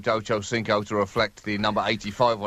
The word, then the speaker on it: Ochocinco: Ochocinco.